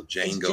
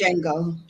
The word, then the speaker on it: Django: I think they said the D is silent, so Django. – It's just Django.